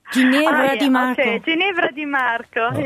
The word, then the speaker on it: Ginevra Di Marco: Ginevra Di Marco? Mit Amara Terra Mia? – Ginevra Di Marco.